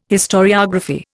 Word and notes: historiography: Indian

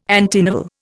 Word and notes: antinovel: Indian

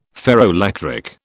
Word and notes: ferroelectric: British English

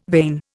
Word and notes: vein: Indian

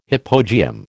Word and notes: hypogeum: American English

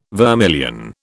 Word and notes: vermilion: British English

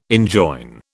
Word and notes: enjoin: British English

